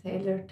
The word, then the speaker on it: Taylor Swift: Also ich habe gemerkt, ich habe momentan eine ultra Taylor Swift Phase wieder. Und zwar merke ich einfach, dass... – Taylor Swift? – Was habe ich falsch gesagt? Ist nicht Taylor? Ja, ja, Taylor, Taylor.